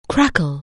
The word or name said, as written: crackle